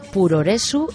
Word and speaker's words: puroresu: Pero es que allí el puroresu es lo más. Puroresu, Profesionaru Wrestling, que es como pronuncian en japonés Professional Wrestling, en inglés, profesional y adecuado, que acortado se queda en esto, puroresu.